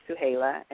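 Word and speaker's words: Suhayla: So it's Real Oaktown 357 on Facebook, and then it's Suhaila Sabir. There's two of them. Both of them are me, Suhaila Sabir. – Wait, can you spell that one more time? – Uh-huh, S is in Sam. U-H-A-Y-L-A, Suhaila, and then the last name is Sabir, S is in Sam, A, B is in boy, I-R.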